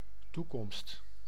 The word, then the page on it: zeggen: [zə.ɣəː(n)]; /ˈzɛ.ɣə(n)/